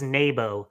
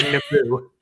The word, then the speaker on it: Naboo: Kind of in the same way George Lucas says it's Nabo instead of Naboo. – That's Naboo. – It's Naboo.